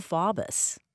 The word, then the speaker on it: Faubus: Orville Faubus. – Yes. – If I pronounce his last name correctly, yep. – I hope. – Yes, you did.